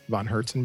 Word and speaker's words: Von Hertzen: V-O-N-H-E-R-T-Z-E-N Brothers, Von Herzen Brothers.